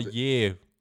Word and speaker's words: Poirier: Zum Beispiel am Wochenende seinen Jungen äh, Pior, äh, Pior, äh, Pior Poirier gegen Conor McGregor zu gucken.